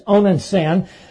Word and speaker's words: Onan: Yes, Onan's sin, doubly monstrous.